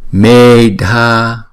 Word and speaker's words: Medha: Medha không, chữ mê, chữ e là hai nhịp đọc là mê. Rồi chữ Dha này là kéo dài hai nhịp và đọc âm bật.